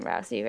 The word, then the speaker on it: Rousey: That's how you say her name, Rousey, right? – Yeah.